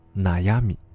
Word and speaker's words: 烦恼: 在日文中，浪矢念作纳米亚，而烦恼。念作纳亚米。